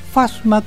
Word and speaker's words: Fasma: Se escribe así, Fasma, con acento en la F-A-S-M-A.